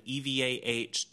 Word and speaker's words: Evah: Eva Destruction, no space, no nothing. EVAH Destruction, the way it's spelled.